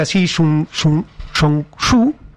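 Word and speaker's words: Xi Zhongxun: Era Xi Zhongshu, así se pronuncia, que elige estas tres zonas precisamente por esta cuestión relevante geopolítica.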